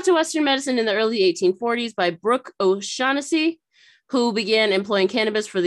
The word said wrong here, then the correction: O'Shaughnessy: You pronounced that wrong. It's okay. O'Shaughnessy. – I was like, I'm pretty sure I didn't, but okay, I'll listen to you. It's, oh yes, you're right. Brooke O'Shaughnessy, who began employing cannabis.